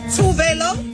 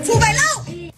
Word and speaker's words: Tuvalu: Hvilket viser sig åbenbart at være en meget rigtig måde at sige det på trods af, at alle gjorde ret meget grin med hende. Det siger Tuvalu i hvert fald selv.